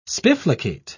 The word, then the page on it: spiflicate: British English